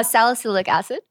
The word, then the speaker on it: salicylic acid: Uh, salicylic acid.